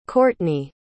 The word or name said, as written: qortni